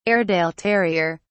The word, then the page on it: airedale terrier: British English